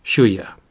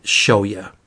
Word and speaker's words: Shuya: And she's very concerned with her older brother, Shuya. Or as Mia told me to pronounce it, Shoya. And that's how I will pronounce it, Shoya.